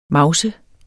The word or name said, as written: mavse